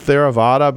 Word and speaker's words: Theravada: And I'll take a shot at pronouncing it Theravada, but that, I'm sure I've got that wrong. – You got it right.